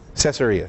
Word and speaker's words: Caesarea: It's very possible that Luke wrote his account of the gospel while Paul was in jail in how do you say that city? Caesarea, thank you.